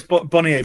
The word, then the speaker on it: Bonnier: How do I pronounce it? Bonnier Books? – Yeah, so it's Bonnier Books, yeah. – Bonnier. – So Bonnier, yeah.